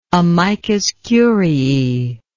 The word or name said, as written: amicus curiae